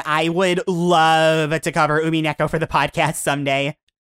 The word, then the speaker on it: Umineko: Is that how you pronounce it? – Umineko, yeah.